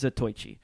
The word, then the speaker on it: Zatoichi: Is that how you pronounce it? – I believe so. – Okay. – It's Zatoichi. My Japanese is very bad, but I believe it's Zatoichi.